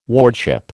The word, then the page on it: wardship: American English